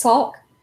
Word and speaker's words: Salk: One name, Dr. Jonas Salk. – Salk. Salk. Thank you.